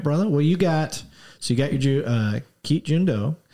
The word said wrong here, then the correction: Jeet Kune Do: You've got am I saying it right? – Jeet Kune Do.